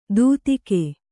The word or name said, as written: ದೂತಿಕೆ